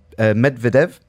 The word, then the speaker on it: Medvedev: There's Daniel Medvedev, if I'm saying that right. – Yeah, that's right.